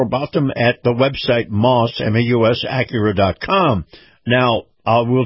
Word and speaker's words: Maus: Moss Acura. Moss is spelled M-A-U-S.